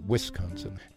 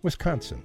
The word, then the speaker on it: Wisconsin: That's how people out of state say it, Wisconsin. – How do you say it? – Wisconsin, the second syllable. – I accent the con.